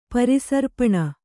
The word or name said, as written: ಪರಿಸರ್ಪಣ